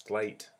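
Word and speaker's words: GLEAT: It's Gleet, as in great.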